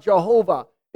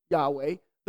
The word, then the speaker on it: Yahweh: The Jewish Encyclopedia says this about Jehovah. It's a mispronunciation of the Hebrew Yahweh, the name of God. This pronunciation is grammatically impossible. The form Jehovah is philological impossibility.